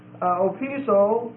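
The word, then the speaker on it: opiso (ὀπίσω): So this first one, opiso, O-P-I-S-O, and the last O is omega, so it's a long O, and it means behind. And then the preposition anti, or anti, A-N-T-I, and this means against or face to face.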